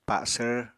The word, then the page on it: pa7svr: Apatani